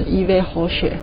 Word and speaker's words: Yves Rocher: 然后我们看到第一个品牌是来自法国的，然后它的拼法是 Y V E S R O C H E，法文是念成 Yves Rocher。